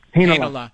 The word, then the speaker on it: Heinola: But then I watched them slide Ville Heinola, I believe. Is that how you say his name? – Heinola. Yeah.